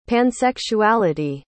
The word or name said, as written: pansexuality